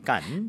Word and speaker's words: Cannes: C-A-N-N-E-S. C-A-N-N-E-S. Now, in French, it's Cannes. – Cannes. – But of course, as you can tell from what I've been saying, Cannes, mm-hmm. a lot of Americans say Cannes.